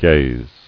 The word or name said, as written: Gazing